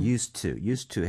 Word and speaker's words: used to: Used 하고 나서. to 이렇게 가면 안 되겠죠. used to 해서 사실 used의 d는 거의 발음이 안 되죠.